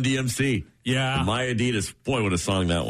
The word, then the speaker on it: Adidas: We pronounce Adidas wrong. It's supposed to be Adidas. Uh, slight emphasis on the first syllable, not an Adidas as we stress the second syllable.